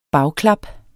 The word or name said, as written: bagklap